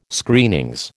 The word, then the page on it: Screenings: American English